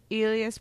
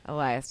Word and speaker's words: Elias: Elias Brothers, is that how you pronounce it? – Elias, sorry.